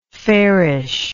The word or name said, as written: fairishly